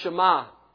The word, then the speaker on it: Shema: The word hear in Hebrew is pronounced Shema.